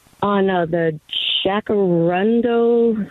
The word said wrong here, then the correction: jacaranda: is that how you say it? – Well, you can call it a jacaranda, jacaranda. You call it whatever you like. It doesn't know its name. In Spanish, it's a jacaranda.